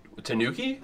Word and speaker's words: Tanuki: Tanuki Mario is the best Mario. – Yeah. – Did I say it right? – Tanuki.